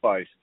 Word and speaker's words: Feist: Trey Feist.